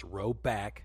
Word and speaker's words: Roback: Now shout out the boys at Roback, which is pronounced Roback, like Brogan Roback.